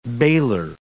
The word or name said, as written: bailer